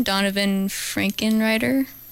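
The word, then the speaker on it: Donavon Frankenreiter: Donovan Frankenreiter? – Yeah.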